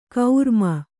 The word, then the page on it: ಕೌರ್ಮ: Kannada